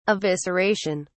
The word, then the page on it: evisceration: British English